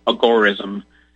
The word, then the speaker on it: agorism: And then, I don't know, probably about 6 years ago, I heard about agorism, and it's kind of a nice middle ground to me. And some people pronounce it agorism. It's named after the Greek agora, the marketplace.